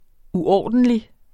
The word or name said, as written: uordentlig